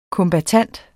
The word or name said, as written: kombattant